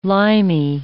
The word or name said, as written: limey